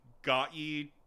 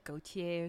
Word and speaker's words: Gotye: It's like Gautier or something. – Gautier.